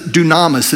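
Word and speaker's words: dunamis (δύναμις): There's another word that's used, and I'll pronounce it dunamis. It's D-U-N-A-M-I-S. It's similar to the word dynamite.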